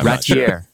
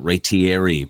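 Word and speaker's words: Raitiere: It's I always screw it up by pronouncing it Ratieri, but there's no E on the end. There's an E on the end, but you don't pronounce it.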